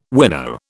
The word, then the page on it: wino: British English